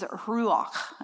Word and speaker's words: ruach: So the Hebrew word for Holy Spirit is ruach, and you almost have to spit on that last syllable there.